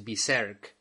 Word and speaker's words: Berserk: Y bueno, y, hablando de, Berserk, por cierto, la, correcta pronunciación en inglés es Berserk.